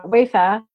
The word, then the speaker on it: Wayfair: I mean, I got a message from a designer, Wayfair, is that how you say it? In the furniture company. – Yep.